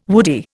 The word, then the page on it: woody: Indian